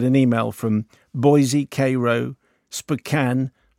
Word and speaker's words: Spokane: In Washington, S-P-O-K-A-N-E. – Spokane. – It's pronounced Spokane. As though there isn't an E.